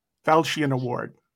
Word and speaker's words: Falchion: Am I going to say this right? Falchion or Falchion? – Falchion Award. – Falchion Award for Best Western.